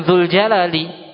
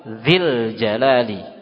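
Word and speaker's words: Zil jalali (ذي الجلال): Maka cara membacanya bukan zul jalali, tapi dibaca apa? Zil jalali.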